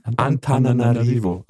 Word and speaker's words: Antananarivo: Ich kann es nicht aussprechen, wie Anan Antananarivo. Antananarivo.